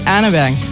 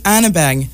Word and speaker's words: Arneberg: Mais c'est pas prononcé comme ça en Norvège, n'est-ce pas? – Non. C'est Ingrid Arneberg. – Il va falloir que tu me répètes ça sans fond. Là. – Ingrid Arneberg.